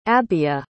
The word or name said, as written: abiah